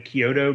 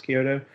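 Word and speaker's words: Chiodo: They're three brothers, and it's like the Kyoto brothers, I think is how it's pronounced, Kyoto.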